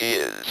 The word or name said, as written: is